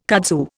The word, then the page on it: kudzu: Indian